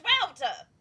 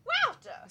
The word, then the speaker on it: WAWTA: Wouta is pronounced Wouta. Like, Wouta. – Someone was very upset at us recently because we spell the name of our podcast W-A-W-T-A and pronounce it Wouta.